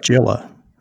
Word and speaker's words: Gila: They had a good Tour Gila, actually, as well. – It's pronounced Gila. It's the Tour of Gila.